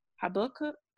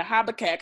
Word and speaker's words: Habakkuk: I always mispronounce it, even though I know it's that. It's like Habakkuk. Yeah, Habakkuk.